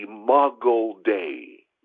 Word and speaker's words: Imago Dei: You and I, every human, is made in God's image, which is called the Imago Dei. The Imago Dei. That's spelled I-M-A-G-O. Second word, Dei, D-E-I.